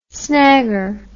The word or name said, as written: snagger